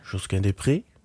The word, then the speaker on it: Josquin Desprez: Josquin Desprez.